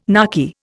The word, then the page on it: narky: Indian